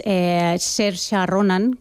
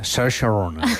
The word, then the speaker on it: Saoirse Ronan: La actriz es Saoirse Ronan, que creo que se pronuncia algo así, se escribe Saoirse, un nombre un poco complicado de pronunciar, pero ella es buenísima.